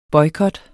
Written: boykot

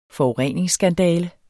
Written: forureningsskandale

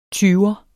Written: tyver

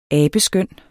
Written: abeskøn

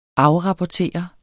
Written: afrapportere

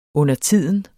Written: undertiden

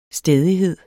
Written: stædighed

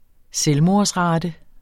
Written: selvmordsrate

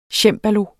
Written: cembalo

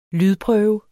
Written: lydprøve

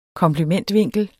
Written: komplementvinkel

